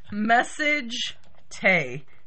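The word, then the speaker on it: Massagetae: It's message tay.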